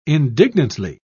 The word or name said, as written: indignantly